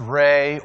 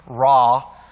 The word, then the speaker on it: Ra: It could be pronounced Re or pronounced Ra. I kind of like calling it Re because it reminds me about who Re was.